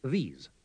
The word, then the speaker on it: these: Oirá que en la primera palabra el sonido de la vocal es mucho más breve. – This. These. – Además, la primera S se pronuncia como la española, mientras que la segunda es una S sonora.